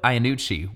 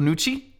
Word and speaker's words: Iannucci: So, hey, Veep was created by Armando Iannucci, which is actually how I thought you say his name, but we were watching the commentary, and he said his name like this, Armando Nucci.